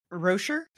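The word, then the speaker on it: wroshyr: How do you pronounce the tree? – Rosher. – Is it Rosher? Is that how yeah, it's it? Rosher.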